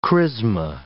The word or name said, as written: chrisma